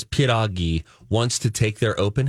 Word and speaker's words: pierogi: Pierogies. – Pierogies. Pierogies. – There you go. You said it right the first time, Mike. – Nobody says pierogi. – Everybody says pierogi. Nobody says piroga.